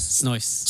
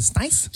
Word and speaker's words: Snow Ice: Is it Snow Ice? – Snow Ice. – Yeah, I was like, is it Snice? – It's so snicey around here.